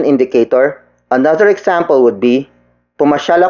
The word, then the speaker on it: pumasyal: Word for word, it's pumasyal ako sa inyo kahapon. Pumasyal ako sa inyo kahapon. Pumasyal ako sa inyo kahapon. The first word is pumasyal. Pumasyal. Pumasyal. Pumasyal is spelled as P-U-M-A-S-Y-A-L.